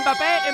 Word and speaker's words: Mbappé: Mbappé. – ¿Quién? ¡Eh! Mbappé, Mbappé.